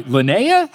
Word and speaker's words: Lynea: So this one comes from, all right, is it Liney? L-Y-N-E-A? Am I Linnea? How do I say it? Nailed it the second time?